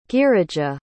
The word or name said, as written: girija